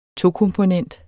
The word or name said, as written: tokomponent